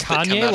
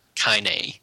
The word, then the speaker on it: Kainé: Kanye, like Kanye West? – Kainé. – Oh, how do I spell that? – K-A-I-N-E.